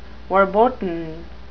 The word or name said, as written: verboten